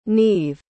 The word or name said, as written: neave